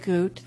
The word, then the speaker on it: Groot: My name is Henriette Groot. That's spelled H-E-N-R-I-E-T-T-E. Groot, G-R-O-O-T.